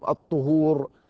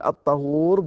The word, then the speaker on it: الطهور: لا تقول عندهم الطهور وإنما تقول الطهور بالفتح. وصاحب القاموس لم يذكر غير هذا، لم يذكر إلا الفتح الطهور، فلم يذكر الضم مطلقا.